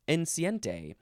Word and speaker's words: enceinte: It is spelled E-N-C-E-I-N-T-E. Now, if I were to read it as Spanish, it would be enceinte. But it's not Spanish. It's French. Enceinte.